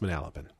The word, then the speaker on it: Manalapan: Is that how you pronounce that? Manalapan? – Yeah. Yeah, Manalapan, but it's pronounced Manalapan.